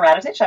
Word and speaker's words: Radetich: And you did such a nice job with my last name. Very few people can pronounce it like that. So I really appreciate that. My name is Nina Radetich.